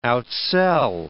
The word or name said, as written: outsell